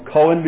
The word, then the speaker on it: Cohen: Anybody know how to pronounce the Hebrew word for priest? It's in your telephone book. Two columns of it in your telephone book. Anybody ever heard the name of Cohen before?